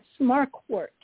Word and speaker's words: Marquardt: Yes, Marquardt.